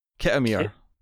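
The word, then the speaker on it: Khitomer: The pronunciation was closer to it's K-H-I-T-O-M-E-R. Kitomir.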